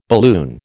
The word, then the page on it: balloon: American English